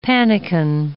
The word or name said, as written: pannikin